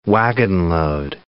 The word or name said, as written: wagonload